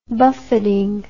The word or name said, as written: buffeting